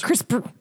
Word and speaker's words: CRISPR: CRISPR spelled without the last vowel. So it's CRISPR. – Crisp with the just crisp and then just the letter R.